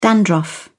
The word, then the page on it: dandruff: British English